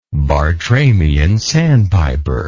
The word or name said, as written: Bartramian sandpiper